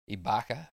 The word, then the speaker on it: Ibaka: Ibaka. Now I'm struggling to pronounce Serge Ibaka's name. – No, you got it.